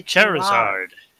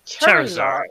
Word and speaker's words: Charizard: I'm going to spell it C H A R I Z A R D. The Charizard. Charizard. Charizard.